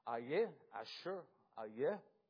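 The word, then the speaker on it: Ehyeh Asher Ehyeh: And in the Hebrew, that is pronounced Ayeh, Asher, Ayeh.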